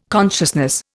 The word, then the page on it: consciousness: Indian